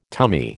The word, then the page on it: tummy: American English